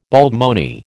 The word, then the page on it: baldmoney: American English